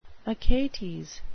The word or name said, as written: achates